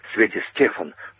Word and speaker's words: Свети Стефан: В такой центральной курортной части Черногории есть такая достопримечательность, остров Святого Стефана, или Свети Стефан, на первый слог делает ударение вообще в разговоре сербы.